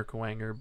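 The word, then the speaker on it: Kuwanger: So they adapted that word to kind of rhyme with boomerang. So boomer, Kowanger, boomer, boomerang.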